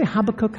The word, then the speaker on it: Habakkuk: If you have your Bible, I'm going to go to Habakkuk. Do we say Habakkuk? Habakkuk. I had to learn Americans say some books differently as well.